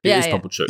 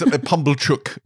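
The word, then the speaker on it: Pumblechook: Have I been calling him Bumblechuck? – Yeah, at one point you did, but I enjoyed it, so I didn't. – But correct. – It is Pumblechuck is obviously the canonical pronunciation.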